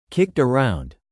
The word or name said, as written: kicked around